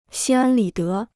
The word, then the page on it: 心安理得: xīn ān lǐ dé